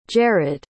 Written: jarrad

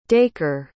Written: daker

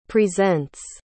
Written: presents

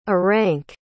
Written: Aranck

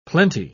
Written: plenty

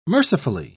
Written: mercifully

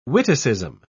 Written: witticism

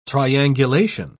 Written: triangulation

